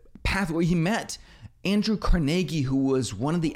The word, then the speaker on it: Carnegie: I first heard about Carnegie Hall, and then I later realized it's pronounced Carnegie.